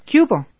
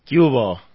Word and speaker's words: Qué hubo: We usually pronounce it a lot faster. ¿Qué hubo? – ¿Qué hubo? – There you go.